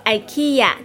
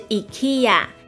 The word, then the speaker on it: IKEA: 或是美国人会说 IKEA，但是瑞典的正确发音是 IKEA。